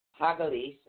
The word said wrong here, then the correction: Hagalaz: excuse me, Hagalaz. – Hagalaz. No, right. It's actually spelled H-A-G-A-L-A-Z, but it's pronounced H-A-G-A-W-L-A-W-S.